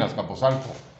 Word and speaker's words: Azcapotzalco: Yo nací aquí en Azcapotzalco. Yo soy azcapolcelca, oh. – azcapolteca, azcapolteca, azcapolteca, Azcapolteca. azcapolzalco, ya no sé ni cómo se dice, la verdad. Azcapotzalco. Okay. Azcapotzalco.